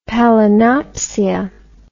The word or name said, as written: palinopsia